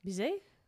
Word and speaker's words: Bizet: And then I thought you should probably take the T off. – Bizet? Okay. – Like ballet.